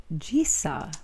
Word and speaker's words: Jisa: This thank you goes out to Valerie, or Val as I was introduced to her, Gisa. Would you say that's how you pronounce her last name? J-I-S-A.